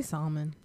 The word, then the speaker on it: salmon: I say salmon. – It's supposed to be salmon. The L is silent, but you know how in Spanish is salmon? – Yeah. – We add the L.